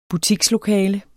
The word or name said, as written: butikslokale